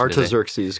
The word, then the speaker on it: Artaxerxes: Artaxerxes, today.